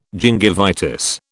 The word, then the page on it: gingivitis: British English